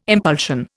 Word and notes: impulsion: Indian

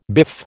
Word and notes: biff: American English

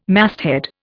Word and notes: masthead: Indian

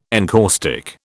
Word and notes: encaustic: British English

